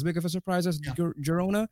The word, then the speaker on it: Girona: I don't know how to pronounce it properly. – Girona, yeah.